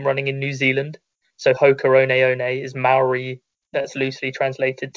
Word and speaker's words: Hoka One One: obviously people have been hearing us pronounce it Hoka One One. It's not Hoka 11 or Hoka 11, which you hear often.